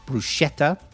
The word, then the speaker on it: bruschetta: B-R-U-S-C-H-E-T-T-A. Okay, all English people say bruschetta. Yeah, but it's not. It's bruschetta. Bruschetta. It's Italian.